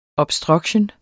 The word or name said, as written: obstruction